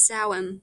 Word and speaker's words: Samhain: It's Samhain.